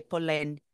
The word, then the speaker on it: Polène: P-O-L-E com acento aberto, N-E, Polene.